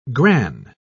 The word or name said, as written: gran